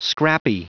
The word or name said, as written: scrappy